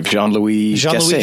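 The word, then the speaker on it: Jean-Louis Gassée: Jean-Louis Gasset.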